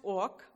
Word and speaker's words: Ok: It's pronounced Ók, but it's spelled OK.